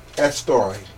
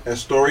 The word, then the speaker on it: Eastre: the offspring Astore. E-A-S-T-R-E. You can pronounce it Astore because they pronounce it a lot of ways.